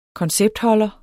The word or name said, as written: konceptholder